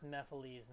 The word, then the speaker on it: Mephiles: Mephiles. – Yeah, it's Mephiles in Sonic 06.